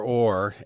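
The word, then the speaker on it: Hoare: Tyler Orr, H-O-A-R-E, a silent H, was the original Snoopy artist, and he's still got a couple planes on a pier in Emeryville.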